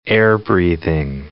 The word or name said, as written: air-breathing